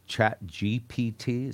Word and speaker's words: ChatGPT: And so this is how I feel about this ChatGPT.